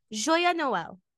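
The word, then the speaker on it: Joyeux Noël: Joya Noel. Airs Sunday, October 29th. Did I say that right? I didn't learn French. – Joya Noel. Joya.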